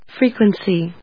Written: FREQUENCY

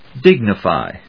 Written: dignify

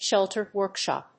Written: sheltered workshop

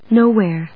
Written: nowhere

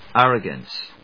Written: arrogance

